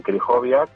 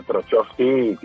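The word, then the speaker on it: Krychowiak: Eh, bueno, pues eh, utilizas la, herramienta esa que os decía de Forbo, que te dice que, que los jugadores polacos o de origen polaco, la CH se pronuncia como una J, entonces tú empiezas a narrar partidos del Sevilla con Trojovski y con Krijoviak y la gente en Sevilla les llamaba Trochowski y, y ¿no?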